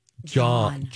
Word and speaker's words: jawn: J-A-W-N. – John.